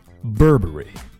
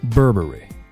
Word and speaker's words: Burberry: Burberry Burberry